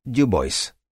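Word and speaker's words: Du Bois: Фамилия Уильяма Дюбуа в русском языке произносится на французский манер, но в США читается как «Дюбойс».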